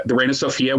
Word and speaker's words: Reina Sofia: Like is it the Reina Sofia? How do you say it? – The triangle in Madrid. Yeah. The Reina Sofia, which houses Picasso's sort of masterpiece.